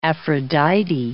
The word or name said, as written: Aphrodite